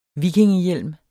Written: vikingehjelm

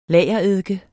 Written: lagereddike

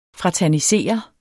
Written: fraternisere

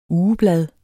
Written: ugeblad

